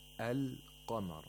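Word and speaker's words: Al-qamar (القمر): If it was a moon letter, the lam would be pronounced. The lam would be a sakin lam. And we have that. Wal-qamari idha talaha. The second verse. Al-qamar. So as you can see, the lam is actually pronounced.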